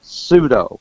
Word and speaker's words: pseudo: Of course, there's the silent P, so some people, if they don't know better, might say pseudo, but we'll use the standard English pronunciation.